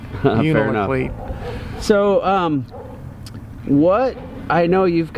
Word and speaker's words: Unalakleet: Unalakleet, Alaska. – Oh, did you say that right? – Yeah, I think so. – I've been leaving the middle L off. Unalakleet.